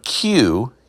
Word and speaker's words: queue: The word Q, Q-U-E-U-E, like a line, standing in a line, standing in a queue. The word Q is just a capital letter Q, followed by four silent letters.